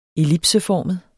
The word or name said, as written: ellipseformet